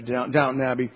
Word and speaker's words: Downton Abbey: Now, so from that, we assume that you can think Downtown Abbey or Downton Abbey. I didn't say that right, did I? Downton Abbey.